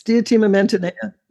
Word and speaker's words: Diotima Mantineia: Yes, yes, Diotima Mantineia.